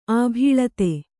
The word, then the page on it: ಆಭೀಳತೆ: kannada